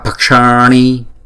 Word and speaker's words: bhakshani: Nè, chữ b và chữ h là ghép lại là b, bhak, bhakshani, bhakshani, bhakshani. Thấy không? Chữ này chúng ta đọc là nó có ba âm nè. Chữ âm thứ nhất là b, b. Chữ b h với chữ a, b.